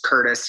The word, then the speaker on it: Curtis: It's Curtis, C-U-R-T-I-S. And my last name is K-U-P-F-E-R-S-M-I-T-H.